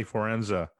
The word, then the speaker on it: Fiorenza: Welcome to the show, Matty Fiorenza.